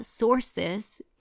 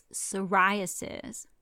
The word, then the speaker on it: psoriasis: It's not psoriasis, it's psoriasis.